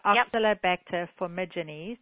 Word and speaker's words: Oxalobacter formigenes: So I want to go back to the Oxalobacter formigenes.